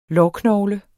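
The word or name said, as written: Lårknogle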